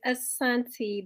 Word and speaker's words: Asanity: Okay, so it's Asanity. The first I is silent.